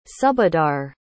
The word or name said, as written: subahdar